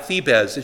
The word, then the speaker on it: Thebes: A Z, yeah, Thebes. It should be two syllables.